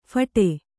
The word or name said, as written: ಫೋಡ್